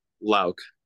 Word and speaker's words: Louck: Yes, it is Louck. Louck? – Okay, perfect. All Louck. Right. So, sorry, what was that? – Louck.